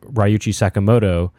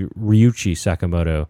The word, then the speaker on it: Ryuichi Sakamoto: On last episode, I mentioned the name Ryuichi Sakamoto, and she wanted to point out that it's actually Ryuichi Sakamoto, and she's right.